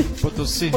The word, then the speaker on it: Potosí: Você da pronúncia? Real Potosí? – Potosí.